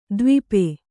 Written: ದ್ವೀಪಿ

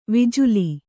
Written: ವಿಜುಲಿ

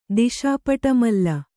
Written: ದಿಶಾಪಟಮಲ್ಲ

